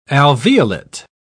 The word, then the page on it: alveolate: British English